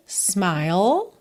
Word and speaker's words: smile: Something in your smile, smile, não pronuncia o E do final.